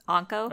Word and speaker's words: Anko: Anko? – Uh-huh.